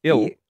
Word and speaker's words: eel: I think you may have pronounced it wrong. – Eel. – Do you reckon? – Eel. – Eel? – You said ill.